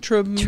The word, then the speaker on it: Tremmel: Tremmel.